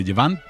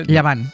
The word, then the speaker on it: Llevant: A mí me quedo muerto con, bueno, Rafa Nadal, el Marqués de Llevant, ¿cómo se dice? – Llevant. – Llevant.